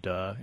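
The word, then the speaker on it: duh (德): So the interesting thing, so another important concept I look at in the book is this, unfortunately it's pronounced duh in Chinese.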